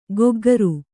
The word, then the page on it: ಗೊಗ್ಗರು: goggaru